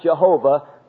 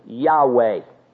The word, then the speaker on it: Yahweh: should have made the pronunciation of this name for God not Jehovah, but literally Yahweh.